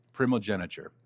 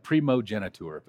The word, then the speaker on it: primogeniture: It looks like primogeniture, but it's primogeniture.